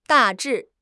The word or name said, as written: 大致